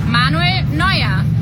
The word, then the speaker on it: Manuel Neuer: Manuel Neuer.